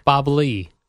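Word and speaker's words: Bob Ley: Jerry, Bob Lay. Is that how you say his name? – Are you serious? Bob Lay? – L-E-Y. – Please tell me that's a joke. – Bob Lee. Lay. Lee? – Bob Lee, Al.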